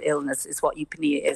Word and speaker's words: eupnea: But it's the medical term eupnea.